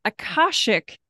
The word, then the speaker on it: Akashic: Her company is Ember Healing, and she is, I gotta say this right, an Akashic Records coach.